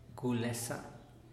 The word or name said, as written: gulesa